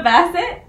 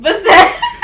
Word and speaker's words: Bassett: Joshua Bassett. – Bassett. – Mm-hmm. Bassett.